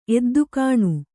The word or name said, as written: ಎದ್ದುಕಾಣು